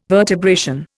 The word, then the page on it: vertebration: Indian